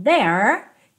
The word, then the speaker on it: there: Nunca diga there, não, o último E não é pronunciado, então there termina ali, ó, com a língua enrolada, igual na porta, com a perna esquerda.